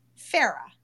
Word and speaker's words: Fera: Fera. – It is Fera. – Yeah. – Oh, like Fera Fawcett. – Well, kind of, but the Italian version.